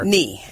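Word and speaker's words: Ne: So I know you also authored a book, The Road to, and is it N-E or is it Knee? I wasn't sure. – Knee. – Road to Knee, and that's spelled N-E, mm-hmm. not like the thing on your body, and so, which is also about goal setting and attainment.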